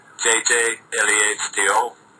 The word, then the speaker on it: JJ Lehto: My name is Jyrki Juhani Järvilehto, better known JJ Lehto, and it's pronounced, JJ Lehto. The real one is Jyrki Järvilehto.